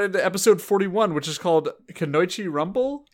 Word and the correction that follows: Kunoichi: Is that uh, how you uh, pronounce Kanoichi? Kunoichi, I think. Kunoichi.